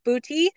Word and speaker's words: Buti: It's spelled B-U-T-I, booty.